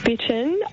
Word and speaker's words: Bittern: You've said it perfectly. Yes. He's from Bitten. – I wasn't sure if it was Bittern, Bitten.